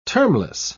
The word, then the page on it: termless: British English